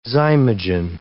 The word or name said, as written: zymogen